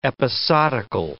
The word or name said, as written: episodical